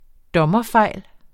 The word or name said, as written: dommerfejl